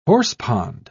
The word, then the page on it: horsepond: British English